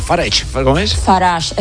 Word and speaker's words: Farage: Farage. – Farage.